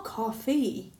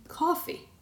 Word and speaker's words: coffee: It's not coffee. It should be coffee. You're not stressing the correct syllable.